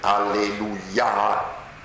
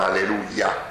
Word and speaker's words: alleluia: Hallel significa lode, alleluia. E non alleluia, alleluia, con l'accento sulla A, perché è un aggettivo nei confronti di Dio,